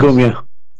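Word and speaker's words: Ngumoha: and it ends with Rio Ngumia. How do you say it? – Ngumia. – Ngumia? – Yep.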